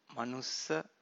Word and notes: manussa: Pali

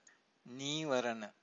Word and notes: nivarana: Pali

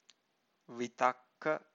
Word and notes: vitakka: Pali

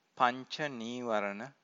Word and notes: pancanivarana: Pali

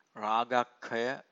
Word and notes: ragakkhaya: Pali

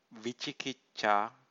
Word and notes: vicikicca: Pali

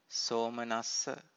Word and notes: somanassa: Pali

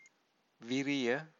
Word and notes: viriya: Pali

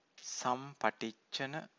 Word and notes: sampaticcana: Pali

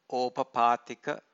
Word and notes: opapatika: Pali

